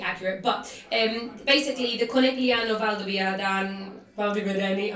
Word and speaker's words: Conegliano: there is one town called Conegliano. We all know that that's how it's pronounced.